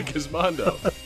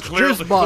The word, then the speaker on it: Gizmondo: I was playing my Gizmondo. – Excuse me. No, it's pronounced Gizmondo.